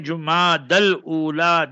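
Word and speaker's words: Jumada al-Ula: J-U-M-A-D-A-L. Then Jumadal Ula, then U-L-A. So Jumad al-Ula. That's the proper pronunciation.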